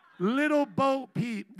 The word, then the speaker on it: Little Bo Peep: What was the one that Woody was with that had to, Bobo Peep? Little Bo Peep? Bobo Peep. Little Bo Peep.